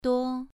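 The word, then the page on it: duo: tone 2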